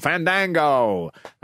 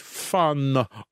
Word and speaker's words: Fandango: They would say, please welcome Fandango. And he would go, no, it's Fandango.